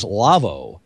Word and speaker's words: Lawo: First of all, Telos and the Telos HX6, and we welcome a new sponsor, and that is Lavo, L-A-W-O. We'll try to get an exact pronunciation of it.